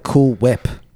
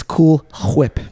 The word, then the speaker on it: Cool Whip: You forgot to pronounce the H when you said Cool Whip before. – Oh, that's because I was angry. I'm sorry. – Yeah, you just called it Cool Whip.